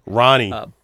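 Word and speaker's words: Ronee: But I don't know how to pronounce her first name. Roni or Ronnie? – Ronnie.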